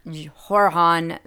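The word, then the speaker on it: Joran: and she was last seen leaving with Jorhan. I don't know how to say his name. It's like, you want to say Jordan, but it's Jorhan Vandersloot. He's Dutch.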